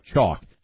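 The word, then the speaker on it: Choq: Chalk. That's C-H-O-Q spelled with a Q.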